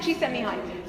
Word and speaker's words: Csikszentmihalyi: Mihai Csikszentmihalyi.